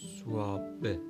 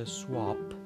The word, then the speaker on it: swab: ببینید اگر ما این کلمه رو با مثل پ توی یک واژه مثلا پدر با پ تلفظ بکنیم خب میشه سواپ سواپ در انگلیسی به معنی مبادله هستش